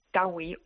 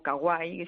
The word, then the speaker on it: kawaii: Tiene una estética que en Japón se llama kawaii o kawaii, según lo pronunciamos a la española, ¿no?